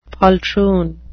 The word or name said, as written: poltroon